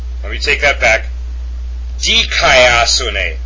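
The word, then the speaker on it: Dikaiosune (δικαιοσύνη): Dikaiosune. The accent is over the upsilon.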